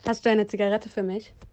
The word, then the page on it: Zigarette: German